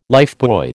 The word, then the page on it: lifebuoy: American English